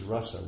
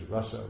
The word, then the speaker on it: rasa: Remember, the word is rasa with a short A. It's a rasa dance with a long A, but it's rasa with a short A.